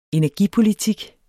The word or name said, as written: energipolitik